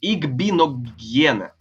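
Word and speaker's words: Igbinoghene: Они взяли также Остин Джексона, Текла и Но Игогена. Да. Игбиногена. Правильно, так, по-моему, фамилия произносится.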